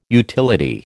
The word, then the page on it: utility: American English